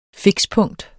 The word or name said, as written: fikspunkt